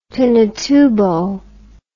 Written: pinatubo

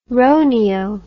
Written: roneo

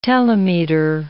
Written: telemeter

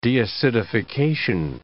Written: deacidification

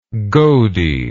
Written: godey

